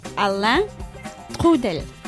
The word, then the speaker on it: Alain Trudel: Okay, let's hear you pronounce it. – Alain Trudel. – Is that good? – Oh, it's great.